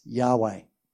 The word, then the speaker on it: Yahweh: In Hebrew, that name is pronounced Yahweh.